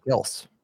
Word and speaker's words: Kelce: Travis Kelce corrected the pronunciation of his last name. – What? – He said it's no way. – Kelce, and he just didn't want to. – He's claiming Kelce now.